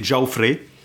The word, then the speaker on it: Jaufré: Celle de la traduction de René Lavaux et René Nelly, dont eux, ils suggèrent que l'original devrait se prononcer Geoffrey.